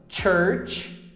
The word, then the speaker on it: church: A segunda pronúncia é de ch, como se você tivesse espirrado. Ch, cha, che, chi, cho, chu. Ch. Como nas palavras church e coach.